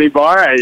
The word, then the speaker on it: Ybarra: Dustin Ybarra, yeah.